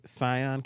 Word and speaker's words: Tsion: It's going to be at the Scion Cafe, but it's spelled T-S-I-O-N.